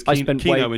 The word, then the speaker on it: Kino: Because Kino in German is cinema. – Is it? Wow. I did not know. What, spelled like that? – No, K-I-N-O, but still. – Still. – Pronounced the same. – I did not know I made a pun. – That's Keno.